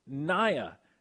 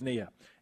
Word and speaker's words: Nia: Her name is Naya with a long I. Well, on this show, she's Nia.